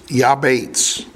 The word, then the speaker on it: Jabez: In Hebrew, pronounced Yabetz, the word Jabez means pain.